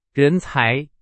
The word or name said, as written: 人才